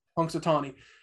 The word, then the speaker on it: Punxsutawney: Punxsutawney.